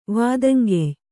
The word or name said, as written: ವಾದಂಗೆಯ್